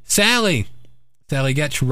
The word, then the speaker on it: Getch: Sally Getch rhymes with sketch.